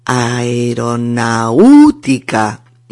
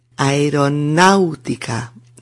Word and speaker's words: aeronáutica: Y la palabra aeronáutica, fijaos con acento en la A, porque si te equivocas y acentúas la U, suena así, aeronáutica, aeronáutica, y eso es incorrecto, es aeronáutica, ¿de acuerdo?